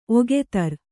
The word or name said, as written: ಒಗೆತರ್